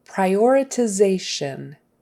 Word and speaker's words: prioritization: Prioritization is the first word. Prioritization. And notice the syllable stress is on zay, that next to the last syllable.